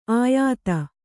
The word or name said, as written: ಆಯಾತ